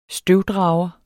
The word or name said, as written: støvdrager